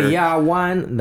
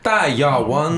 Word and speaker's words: Daya Wan: Daya Wan. That's the Chinese pronunciation. – That's how you say it. Yeah. It's like awesome. That. That's it. Daya Wan.